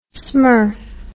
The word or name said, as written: smurf